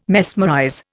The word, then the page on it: mesmerize: Indian